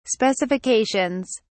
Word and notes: specifications: British English

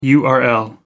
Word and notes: url: American English

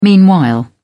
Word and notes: meanwhile: British English

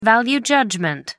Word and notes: value judgement: American English

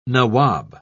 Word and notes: nawab: British English

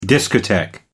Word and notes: discotheque: American English

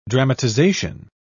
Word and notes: dramatisation: British English

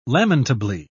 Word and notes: lamentably: British English

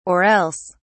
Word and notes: or else: British English

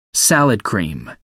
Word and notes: salad cream: American English

